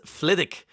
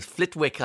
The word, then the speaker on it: Flitwick: Richard from Tullamore is back to us, and he says, Flitwick has a silent W. Flitwick, I was calling it.